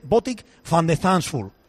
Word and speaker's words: Botic van de Zandschulp: Pues mira, ahora cuando estamos, cómo se pronunciaba Soyuncu o Soyuncu o lo que sea del Atleti, pues lo mismo, entonces el propio jugador diciendo cómo se pronuncia y se dice Botic van de Zandschulp.